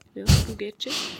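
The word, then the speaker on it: Bu Gece: Auch den hatten wir schon mit, ich glaube, man spricht es Bugece aus. – Würde ich auch so aussprechen, ja. – Bugece.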